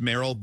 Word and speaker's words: Meryl: I know it's pronounced Meryl, but if the last name's Gummer, I'm going to go Merle Gummer.